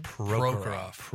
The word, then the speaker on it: Prokhorov: Prokhorov.